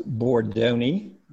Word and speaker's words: Bordoni: The last name is pronounced Bordoni, so that's an Italian name from long ago.